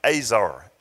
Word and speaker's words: ezer: The word for helper in the Bible is a Hebrew word, and it's spelled E-Z-E-R, but it's actually pronounced Azer, A-Z-E-R. That's how it's pronounced.